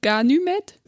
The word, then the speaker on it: Ganymed: Da es griechisch ist, würde ich sagen, dass Y wird für mich als Ü ausgesprochen auf Deutsch, also Ganymed.